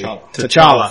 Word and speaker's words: T'Challa: B, T'Challa. Can't remember how they said it in the movie. T'Challa. Thank you. T'Challa.